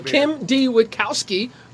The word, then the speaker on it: Witkowski: And Kim D. Witkowski, correct pronunciation? – Yeah.